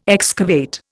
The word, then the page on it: excavate: Indian